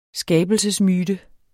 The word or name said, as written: skabelsesmyte